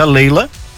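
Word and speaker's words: Layla: Eu conheço uma música que se escreve Laila, mas se pronuncia Leila, do Eric Clapton, que é maravilhosa a música. – Entendi. – Leila, Catherine, Chica, Tura, muito bonito.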